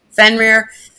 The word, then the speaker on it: Fenrir: Fenrir.